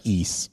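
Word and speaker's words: Ys: East. – Y-S. – Oh, okay. – Yeah, it's pronounced East.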